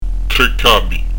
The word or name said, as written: capi